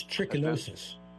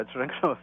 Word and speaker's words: trichinosis: it's trichinosis. – Yeah, trichinosis. Yeah. T-R-I-C-H-I-N-O-S-I-S.